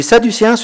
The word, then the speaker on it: sadducéen: Les sadducéens, ce sont un peu les intellos de l'époque, très attachés à la raison. Ils se revendiquent de la descendance de Tsadok. C'est pour ça que ça se prononce sadducéen.